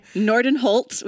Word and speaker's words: Nordenholt: Nordenholt with a T, but you're close.